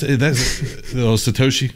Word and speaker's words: Satoshi: Tasahoshi, or whatever how you say it. – That's Satoshi, stacking sats.